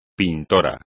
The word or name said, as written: pintor